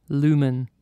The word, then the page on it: lumen: British English